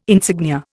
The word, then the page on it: insignia: Indian